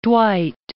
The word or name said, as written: Dwight